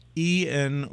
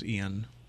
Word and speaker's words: Ian: The real question, is it pronounced E-N or Ian? I thought it was Ian. – I didn't realize there was more than one way to pronounce it. – Yeah, we have a news director here named Ian, so that's obviously the way to do it.